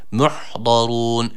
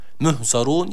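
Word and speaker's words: muhdarun (مُحْضَرُونَ): Muhdarun. Birincisi ha harfi, sakin olan ha harfine dikkat edelim. Bunu he ile okumayalım. Muh, muhzarun yanlış okuyuş olur.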